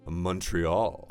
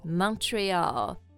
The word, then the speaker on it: Montreal: Oh, 我特别喜欢蒙特利尔，就法语区嘛。这 Montreal, 蒙特利尔的拼法是 M-O-N-T-R-E-A-L. – Right. – 所以我们会看到它的后半部分就像英文的那个 Rio. – It looks like Rio. – 对。所以有可能你想读成Montreal. But actually the pronunciation is Montreal. – 所以它最后面是一个 all 的音、就好像 oh, 我明白了 oh, 我 get 它应该发 Montreal.